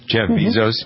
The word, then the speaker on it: Bezos: Amazon customers in the next 4, 5, 6 years, according to the CEO of Amazon, Jeff Bezos. Isn't that how you pronounce his name? – Mm-hmm. – Jeff Bezos.